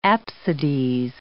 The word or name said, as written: apsides